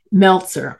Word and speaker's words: Meltzer: We had this book autographed, my goodness, to my mother, and it was by Langston Hughes and Milton Metzler. Okay, Meltzer, I'm sorry, I said his name wrong.